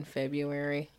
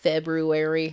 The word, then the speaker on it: February: Do you pronounce the R in February, the first one? No. – February.